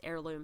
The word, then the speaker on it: heirloom: She is in hell searching for her ancestral home and her family's heirloom hammer. Heirloom and hammer both start with an H, by the way, but only one of them is pronounced.